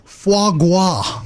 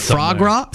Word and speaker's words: foie gras: I thought it had an R in it. – Frog gras? – It's silent. Foie gras.